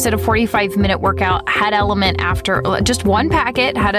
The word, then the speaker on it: LMNT: like Element, and that's spelled L-M-N-T.